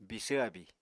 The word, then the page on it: bisvr abíi: Apatani